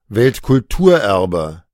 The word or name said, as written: Weltkulturerbe